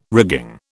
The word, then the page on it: rigging: British English